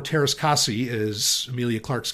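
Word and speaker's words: Teräs Käsi: And she says, it's Taras Kasi. Which Taras Kasi, I thought, was pronounced and has been pronounced by a lot of people for many years, Taras Kasai,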